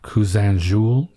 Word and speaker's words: Cousin Jules: It puts me in the mind, in fact, of a film like Cousin Jules. Tell me about my pronunciation there. – That was beautiful.